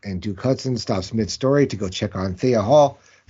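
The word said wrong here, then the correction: Thea Hail: Thea Hale, I always pronounce that wrong.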